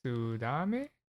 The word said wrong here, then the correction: Suzume: Is that something with Suzume? An S? Suzume? Oh, that sounds right. Suzume.